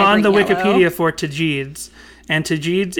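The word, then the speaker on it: Tagetes: T- Let's see what uh how does how does Siri gonna pronounce this? Hold on. Uh Tajids. Tajids? This says Tajids.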